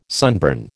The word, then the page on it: sunburn: American English